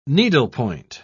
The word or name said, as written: needlepoint